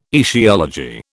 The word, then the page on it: etiology: British English